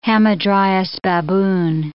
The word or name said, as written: hamadryas baboon